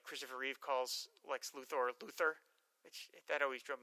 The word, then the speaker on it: Luthor: And he also mentions, I also appreciated how you later pronounced Lex Luthor, emphasis on the O. On TV, e.g. Smallville and Supergirl, it's typically incorrectly pronounced Luther.